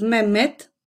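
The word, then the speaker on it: Mehmed: Очень часто в виде диалекта можно буквы «хэ», если она не стоит в начале слова, вообще не услышать. Так имя «Мехмед» превращается в «Мемед», приветствие «Мерхаба» в «Мераба», а вопрос «Как дела?» «Нехабер» – «Неабер».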